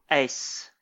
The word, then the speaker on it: Uys: So this film is directed by Jamie how do you pronounce this last name? Do you know? – Ace. – Ace. Um, yeah, yeah. You, um, it's technically not uh, not the same as the, uh, the ace card in in your deck of cards, but if you pronounce it that, that that way, no one will give you the side eye.